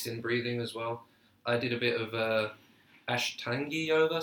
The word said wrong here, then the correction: Ashtanga: Ashtanga, whatever.